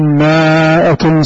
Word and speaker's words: مائة: حروف مرسومة في القرآن الكريم ما تنطق لا تقرأ. فالإنسان يعتمد على أنه صاحب الشهادات ويأخذ المصحف ويقرأ إن يكن منكم مائة صابرة يغلبوا مائة خطأ هذا يا أخي صحيح مرسوم هكذا مائة مرسوم هكذا لكن هل تقرأ هكذا؟